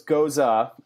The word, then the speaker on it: Gose: So it's Goza. G O S E Goza, pronounced that way.